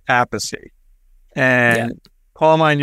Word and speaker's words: Appice: But my father said Appice. Carmine used to say Appice. So I'm Appice. – So we're sticking with Appice.